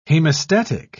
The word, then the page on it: hemostatic: British English